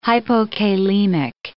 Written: hypokalemic

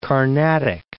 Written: Carnatic

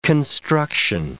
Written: construction

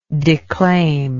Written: declaimer

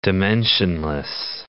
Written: dimensionless

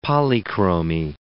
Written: polychromy

